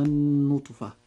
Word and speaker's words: النطفة: كلمة النطفة نجد أن حرف النون في هذه الكلمة عليه شدة وضمة، وهذا الحرف المشدد أي النون يجب إظهار غنه بمقدار حركتين، فنقول هذه الكلمة هكذا.